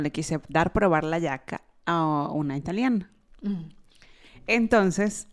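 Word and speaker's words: giacca: Ok, en italiano la yaca es una chaqueta y se pronuncia así, la yaca.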